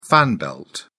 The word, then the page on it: fan belt: British English